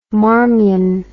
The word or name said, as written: Marmion